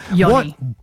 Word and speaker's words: yoni: how do I pronounce this one? – Yoni. – What is a yoni another name for? – A yoni? – Yeah, yoni. Y-O-N-I, like a yoni.